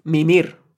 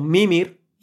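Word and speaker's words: Mímir: Pues a veces pronuncian su nombre como Mimir y otras Mimir. La verdad, y los mismos personajes lo pronuncian Mimir, Mimir. Bueno, es que no se acaban de aclarar, no tendrían una indicación clara. Cory Barlog en las entrevistas le llama Mimir.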